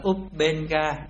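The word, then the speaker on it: ubbeṅgā: Tiếp Ubenga Piti, chữ này đọc là Ubenga, Ubenga Piti, tức là khinh phỉ là khi phát sinh làm cho cảm giác nhẹ nhàng dường như bốc lên cao.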